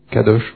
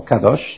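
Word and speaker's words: kadosh (קדוש): Hebrew is kudosh. – L'hébreu c'est kadosh en fait. – Oh, kadosh. I turn for the right pronunciation.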